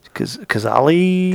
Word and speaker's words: Cazale: C-A-Z-A-L-E? Cazale? – Cazale? – Cazale.